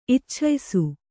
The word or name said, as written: ಇಚ್ಛಯಿಸು